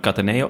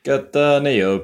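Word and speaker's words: Cattaneo: Heren, nog even naar aanleiding van de discussie aangaande de klemtoon op de naam Cataneo. Als je nou de volgende keer dit liedje in je hoofd neemt, kun je lekker de klemtoon leggen waar je wil. Het gaat allebei.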